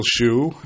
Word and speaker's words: Chu: My name is Michael Chu, and I know by reading my name you don't know how to pronounce it, but it is like the shoe on your foot, as I think Jim communicated before.